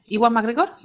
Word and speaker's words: Ewan McGregor: Vale, bueno, pues tenemos la tercera temporada de Fargo con Ewan McGregor, ¿lo he dicho bien? – Sí, correcto.